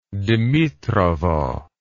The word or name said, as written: dimitrovo